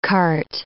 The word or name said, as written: cart